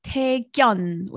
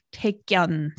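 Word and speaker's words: Taekkyeon: So the first one is called Taekyeon with a double G. Taekyeon, so strong G. – Taekyeon. I have heard of this one, but I don't remember exactly what it was. – So it's spelled T-A-E. So it's Tae and then K-K-Y-O-N, Gyeon. So not Taekyeon, but Taekyeon.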